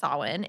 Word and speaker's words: Samhain: And if you see it spelled out, it's Samhain. So, but it is pronounced Samhain,